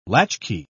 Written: latchkey

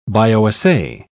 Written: bioassay

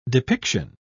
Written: depiction

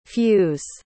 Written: fews

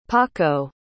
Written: paco

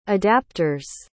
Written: adapters

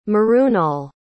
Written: mrunal

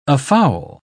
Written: afoul

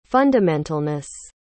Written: fundamentalness

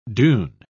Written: dune

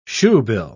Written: shoebill

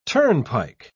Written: turnpike

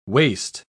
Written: waist

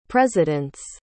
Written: presidents

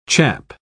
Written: chap

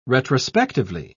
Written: retrospectively

